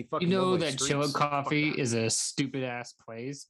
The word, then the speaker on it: Chillicothe: It, so how it's spelt is Chillicoth, but they're just like it's called Chilla Coffee, bro.